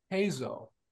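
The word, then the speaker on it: Heizou: Farjan, Haizhou? Haizhou? – Hazo. – Hazo.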